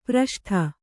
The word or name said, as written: ಪ್ರಷ್ಠ